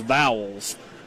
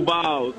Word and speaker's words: Vols: I think you need to say Vols. How do you say it, Coach? – Go Vols.